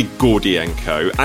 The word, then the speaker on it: Gordienko: I enjoy Gordienko, and it also is one of those few Eastern European surnames which is pronounced exactly as it's spelt, which is very, very enjoyable.